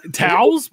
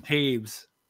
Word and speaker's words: Toews: Towels? What? How do you? What? What's your name? – Taves. – Taves. T-A-V-E-S. That's how it's pronounced.